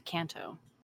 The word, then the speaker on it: Kanto: It's actually Kanto, but it's been Americanized to Kanto. – Okay. – So in Japanese, vowels, the characters always make the same noise. So A is always A. So Kanto is the proper pronunciation of Kanto.